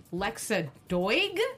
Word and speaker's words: Doig: So, anyhow, and that is Lexa, I don't know if I'm going to say this right, D-O-I-G, Lexa Doig? – Where is it? Doig. – Yeah, I would say she's from Lexa Andromeda Doig. Continuum, Stargate SG-1, and the 4400, if yep. that's how you say it.